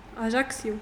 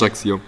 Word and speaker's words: Ajaccio: Ajaccio. – Ajaccio, okay, das ist echt mega schwer zu aussprechen. – Doch, das stimmt schon, das ist schon richtig. Ajaccio.